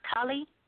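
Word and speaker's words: Thale: It's spelled T-H-L-E, but I found out it was pronounced Tale.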